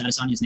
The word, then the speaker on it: Adesanya: Everyone still says Adesanya's name wrong, even me. Like, his name is like Adesanya. You're supposed to fucking emphasize that. He says it himself.